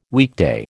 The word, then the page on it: weekday: American English